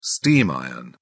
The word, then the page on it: steam iron: British English